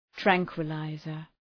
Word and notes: tranquilizer: ‘træŋkwə,laızər

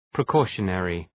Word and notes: precautionary: prı’kɔ:ʃə,nerı

